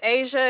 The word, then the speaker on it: Asia: My name is Asia. It is spelled A-S-I-A.